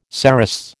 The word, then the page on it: cerastes: American English